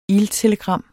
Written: iltelegram

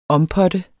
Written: OMPOTTE

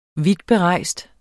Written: vidtberejst

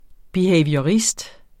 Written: behaviorist

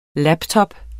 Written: laptop

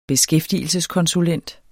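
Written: beskæftigelseskonsulent

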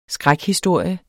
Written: skrækhistorie

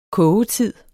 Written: kogetid

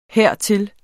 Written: hertil

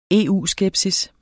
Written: EU-skepsis

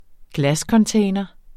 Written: glascontainer

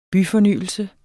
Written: byfornyelse

